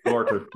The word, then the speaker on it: Gorter: Gorter, yeah.